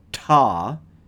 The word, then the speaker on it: Ptah: There is a long-standing Egyptian belief that the original creator god, Ptah, and that's spelled P-T-A-H, there is a long-standing belief that the original creator god, Ptah, is often represented on earth in the form of a bull.